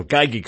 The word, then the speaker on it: Geigy: Novartis evolved from the Sandoz, Cyba, and Geigy companies, or Geigy companies, excuse me, G-E-I-G-Y, Cyba, C-I-B-A, I must maybe mispronouncing those.